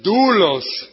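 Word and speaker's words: doulos (δοῦλος): Apúntalo, es doulos. Ok, apúntalo, es D-O-U-L-O-S. Doulos. Es doulos, pero se pronuncia doulos. Ok, D-U-D-O, la D de dedo, O-U-L-O-S. Doulos. D-O-U.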